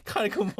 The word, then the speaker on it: Kai Gunmu: Oh, did I pronounce it in English? Like, Carl Gunmu? – No, Kai Gunmu. Okay. – Yes, Kai Gunmu.